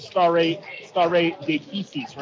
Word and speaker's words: stare decisis: You've heard of stare decisis, right? See what I did there, Robin? – Yes, thank you for correctly pronouncing the Latin.